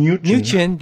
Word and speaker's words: Mnuchin: Mnuchin.